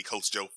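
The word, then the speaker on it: Coach Jeaux: Coach Joe. Follow her on IG at Coach Joe. – Yes. J-E-A-U-X. – Oh, yeah. You got to spell it the Louisiana way.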